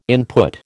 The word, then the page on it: input: American English